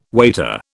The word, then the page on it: waiter: British English